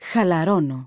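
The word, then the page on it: χαλαρώνω: Greek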